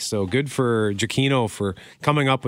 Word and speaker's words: Giacchino: Is it Giacchino? Is that how you pronounce his name? – Yeah, Michael Giacchino of uh, Lost fame.